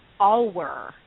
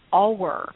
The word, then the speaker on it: Auweier: This game is called Auwer, and it's spelled A-U-W-E-I-E-R. Auwer.